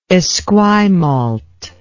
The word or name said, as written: Esquimalt